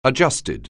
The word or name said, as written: adjusted